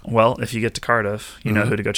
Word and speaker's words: Cardiff: Did I say that right? Cardiff? – Yes, you did.